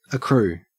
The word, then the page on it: accrue: Australian English